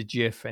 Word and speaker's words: GIF: Well, yeah, there really can be no argument because it was Stephen himself, the creator, who said it's a soft G. It's a GIF, and he said it multiple times, and he's made it very clear.